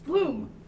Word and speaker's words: Blohm: It's Blohm. Like the flower.